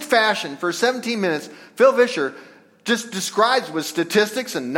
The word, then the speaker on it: Vischer: A couple of weeks ago or so, Phil Vischer, kind of spelled like Fisher, but Vischer.